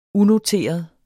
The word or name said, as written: unoteret